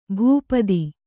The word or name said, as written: ಭೂಪಥ